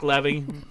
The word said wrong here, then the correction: Levi: Yeah, he got, or Levi. How do you say his last name? – Levi. Zachary Levi.